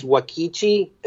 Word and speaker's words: Uakti: I got into this band Wakti, U-A-K-T-I, and I think it's pronounced Wakichi, and they're from Brazil.